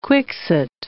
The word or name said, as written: quixote